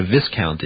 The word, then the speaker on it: viscount: viscount is. – V-I-S-C-O-U-N-T? – Yes. – You mean Viscount? – Is that how they pronounce it? – Uh-huh.